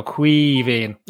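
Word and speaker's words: Caoimhín: Think the best way to imagine how to pronounce it, Neil, is imagine you're half pissed, right, and just go Quivín, and there you go. That's simple. – Like an E, but it's an I, isn't it?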